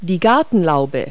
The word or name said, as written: gazebo